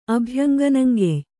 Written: ಅಭ್ಯಂಗನಂಗೆಯ್

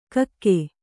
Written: ಕಕ್ಕಿ

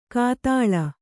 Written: ಘಾತಾಳಿ

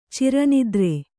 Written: ಚಿರನಿದ್ರೆ

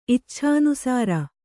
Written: ಇಚ್ಛಾನುಸಾರ

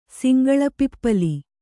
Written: ಸಿಂಗಳಪಿಪ್ಪಲಿ

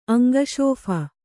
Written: ಅಂಗಶೋಫ

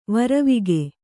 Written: ವರಿವಿಗೆ

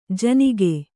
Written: ಜಿನುಗು

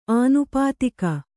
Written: ಅನೌಪಾಧಿಕ

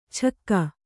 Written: ಛಕ್ಕ

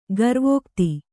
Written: ಗರ್ವೋಕ್ತಿ

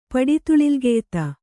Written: ಪಡಿತುಳಿಲ್ಗೆಯ್ತ

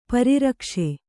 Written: ಪರಿರಕ್ಷ

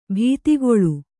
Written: ಬಾತುಗಾಲು

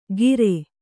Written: ಕಿರೆ

